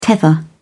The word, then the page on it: tether: British English